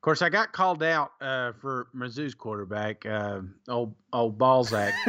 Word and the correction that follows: Bazelak: or how you say his name? – Bazelak. – Hey, Bazelak, I'll never say that right. I'm going to butcher that till he retires.